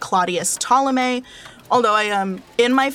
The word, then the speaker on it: Ptolemy: and she told me that it is supposed to be pronounced Ptolemy. Like, you're supposed to pronounce the P.